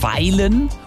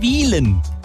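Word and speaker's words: Wyhlen: Ortsfremde sagen oft Weilen oder sogar Wühlen. Es heißt aber Wielen, auch wenn es mit Y geschrieben wird.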